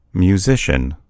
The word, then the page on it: musician: American English